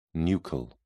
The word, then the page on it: nuchal: British English